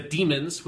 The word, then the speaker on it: Daemons: John Pertwee, we are going to watch the story The Demons, which is spelled D-A-E-M-O-N-S, so you can call it Daemons if you want.